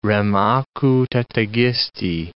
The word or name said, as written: rem acu tetigisti